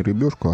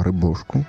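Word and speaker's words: рыбёшку: Ну, кстати, сейчас правильно говорить не рыбешку, а рыбешку.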